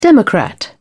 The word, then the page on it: democrat: British English